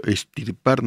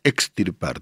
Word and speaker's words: extirpar: Eh, Josué no sé qué dice, pero estirpar no se dice, se dice extirpar, te falta una X.